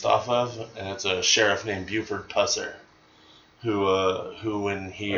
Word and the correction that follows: Pusser: Are you sure you're saying that right? – Buford Pusser. Okay. Yeah, B-U-F-F-O-R-D. – B-U-F-F-O-R-D.